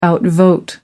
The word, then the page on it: outvote: American English